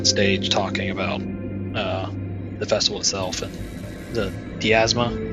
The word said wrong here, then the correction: Pharasma: that the god, did I say that right? – Pharasma. – Pharasma, sorry. Yeah, where he's talking about Pharasma and, you know, the cycle of souls.